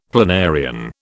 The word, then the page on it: planarian: British English